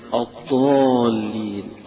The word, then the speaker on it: الضالين: لكن لا تنطق الضاد ضاء زي ما البعض يقول لك هي هذا مخرجها هذا وتقرأ، ولا الضالين يقرأها قريبا من الضاد أو يقرأها كالضاد، هذا ليس بصواب.